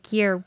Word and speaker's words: Gere: Some say Gere was alone. What, did I say that right? – Yes.